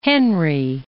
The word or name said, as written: Henri